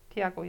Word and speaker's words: Thiago: Wie spricht man das Pferd aus? Thiago. – Thiago, ja.